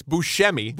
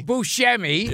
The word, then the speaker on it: Buscemi: First of all, you dishonor him again by mispronouncing his name. – Oh, now it's Buscemi. – Buscemi.